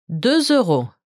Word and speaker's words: 2 euros: Note that there is an S to indicate the plural form, but the S is silent. Together it's 2 euro.